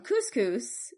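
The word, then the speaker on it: Cuscus: And Arcadius, just to be petty, extended John's exile from Couscous, which is where he was currently. – Couscous? Like the thing you eat? – It's not spelled the same way. It's C-U-S-C-U-S, but yes, in Cappadocia.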